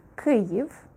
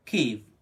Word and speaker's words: Kyiv: The proper pronunciation would be Kyiv. – So try it once again. Kyiv, Kyiv, Kyiv.